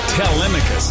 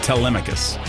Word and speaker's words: Telemachus: Telemachus, they're saying. That's how you pronounce that name. Telemachus.